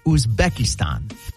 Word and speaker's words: Uzbekistan: Uzbekistan. – The Z is silent. I know that. – No, it's not. It's ooz. Say ooz. – Ooh. – Ooze. – Uzbekistan.